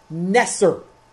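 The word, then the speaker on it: neser (נֵצֶר): In Hebrew, the word for branch is neser. The first syllable of Nazareth.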